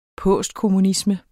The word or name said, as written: postkommunisme